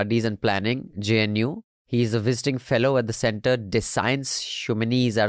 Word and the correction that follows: Centre des Sciences Humaines: Centre des Sciences Humaines. – Okay, that's how you pronounce it, like Himanshu said, not like how I said it.